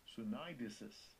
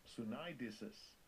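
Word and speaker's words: Sunidesis (συνείδησις): Let's hear the pronunciation of this in the European Greek. – Strong's G, 4893. Sunidesis. Sunidesis.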